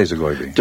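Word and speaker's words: Zogoiby: How do you say Zagoibi? – Just like that.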